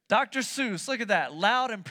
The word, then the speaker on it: Seuss: It's Seuss.